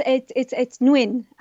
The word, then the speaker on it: Nguyen: You also nominated Kevin Guyon's New Waves book. Is that how we're going to pronounce his name correctly? – Yeah. So it's Nguyen, is how you pronounce it.